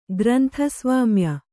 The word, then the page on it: ಗ್ರಂಥಸ್ವಾಮ್ಯ: grantha svāmya